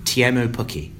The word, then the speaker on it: Teemu Pukki: As he sort of told me afterwards, I checked on the pronunciation, and he said, technically in Finnish, Teemu Pukki, but he's not that worried.